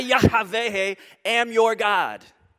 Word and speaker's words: Yahweh: And for years we've pronounced this Jehovah. Probably a better pronouncement, Yahweh or Yahweh would be even more. And this is a proper name.